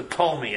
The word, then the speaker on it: Ptolemy: And there is the Ptolemy empire, spelled with a P, the Ptolemy empire, based in Egypt.